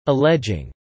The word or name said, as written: alleging